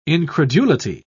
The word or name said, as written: incredulity